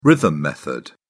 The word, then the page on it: rhythm method: British English